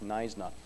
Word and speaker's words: Knysna: it starts with a K, silent K, like knife, Knysna.